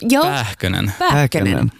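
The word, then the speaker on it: Pääkkönen: Uttalar jag namnet rätt? – Pähkönen. – Ja. – Pähkönen. – Pähkönen. – Mm. Perfekt.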